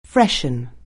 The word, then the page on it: freshen: anglais